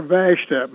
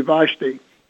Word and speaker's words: Vashti: Or Vashta. My father called her Vashta. I always called her Vashta, but I think probably a more appropriate pronunciation would be Vashti.